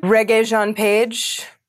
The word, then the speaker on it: Regé-Jean Page: Regé-Jean Page.